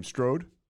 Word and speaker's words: Strode: Strode.